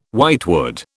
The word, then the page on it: whitewood: British English